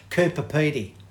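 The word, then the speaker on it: Coober Pedy: How do you say it? – Coopapeedy. – Coopapeedy. – Coopapeedy, yeah. – Coopapeedy. – That's it. Coopapeedy.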